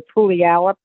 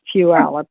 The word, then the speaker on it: Puyallup: Everybody calls it when they first get there, Puyallup, but it's Puyallup, and these things are harsh.